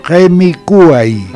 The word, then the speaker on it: gemicuay: Falta el acento. Gemicuay. – Ah, gemicuay. – Claro. – Acento en la U.